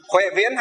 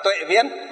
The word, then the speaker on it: Tuệ Viễn: Huệ Viễn hay còn gọi là Tuệ Viễn. Ở trong miền Nam họ đọc là Huệ, còn Việt Nam chúng ta, ngoài Bắc chúng ta đọc là Tuệ, vì chữ Hán á.